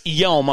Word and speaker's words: Yeom: At 7,250 yards, a 74 rating, and a 138 slope, Chris Yeom, I hope I'm saying that correctly, Y-E-O-M, Chris Yeom is heading home from the Korn Ferry Tour Monday qualifier after shooting 63.